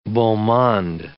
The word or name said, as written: beau monde